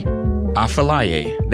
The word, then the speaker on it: Affelaye: The last song you heard before this was Want Me by Afile. Actually, I feel like I'm pronouncing that wrong. That is... Sorry, Afilaye. There we go.